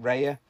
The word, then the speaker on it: Raya: Is it Raya? Yeah, Raya, yeah. You pronounce it Raya.